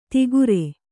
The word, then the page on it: ತಿಗುರೆ: tigure